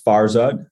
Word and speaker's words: Farzad: My name is Farzad. I know it's kind of hard for some people to pronounce. It's I always say it's like Farquaad and Shrek, but without the Q.